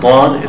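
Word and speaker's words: dod (ض): The letter dod is pronounced from the back part of the mouth, not the tip of the tongue.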